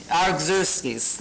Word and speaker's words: Artaxerxes: For example, Nehemiah, he was the cupbearer for King's, Artaxerxes, I can't pronounce it. Artaxerxes, thank you. Who said that? Caroline? Yes, that's what it is.